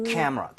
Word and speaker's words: camera: She bought me 여기에만 강세 주면 돼요. – camera. 오. – camera. 근데 진짜 무슨 이름절인 것처럼 라, 라 이렇게 돼야 돼요. camera. 나눠지는 순간 발음이 엄청 어색해집니다.